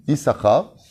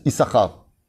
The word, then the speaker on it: Issachar: Issachar. C'est-à-dire, c'est un des rares noms qui est écrit avec deux chines. Nachon. Et on le prononce Issachar. On va dire non, Nachon. Il y a deux chines. Pourquoi il y a deux chines? Pour qu'on coupe le mot en deux.